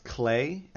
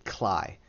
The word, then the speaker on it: Klei: It's an indie survival game that was developed by a Canadian company named Clay Entertainment, and as much as it pains me, it is pronounced Clay, and I always want to pronounce it Cly, but because it's spelled K-L-E-I. But from what I found, it's pronounced Clay.